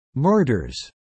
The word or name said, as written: murders